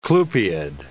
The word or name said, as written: clupeid